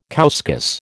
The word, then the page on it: couscous: American English